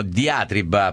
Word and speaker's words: diatriba: È vero, è una diatriba, come abbiamo detto prima, diatriba, perché qualcuno ci ha detto che si può dire anche così perché è nella sua forma latina con l'accento sulla i finale.